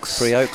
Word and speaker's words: Priaulx: Priaulx, close.